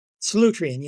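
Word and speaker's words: Solutrean: Solutrine, yes.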